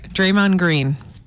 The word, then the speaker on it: Draymond Green: All right, Draymond Green. Did I say his name wrong? – No, you got it.